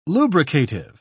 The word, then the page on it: lubricative: British English